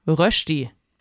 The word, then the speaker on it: Rösti: Wir machen, aufpassen, die lieben Schweizer, ich kann das richtig aussprechen, die Rösti.